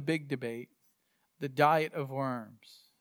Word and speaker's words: Worms: And that's your Diet of Worms. Well, in German, the W is pronounced a V sound. So it's Worms. Worms.